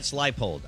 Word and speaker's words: Leipold: Leopold. Show you the money. – Leopold. – Yep. Lance. – Lance Leipold.